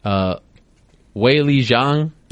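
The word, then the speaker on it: Weili Zhang: Wei Li Zhang. – Yeah. – If I'm saying it correctly, and I do believe I am because I asked her manager, Brian Butler.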